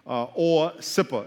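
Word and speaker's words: AWCIPA: Very simple acronym that we're going to use called or sipper.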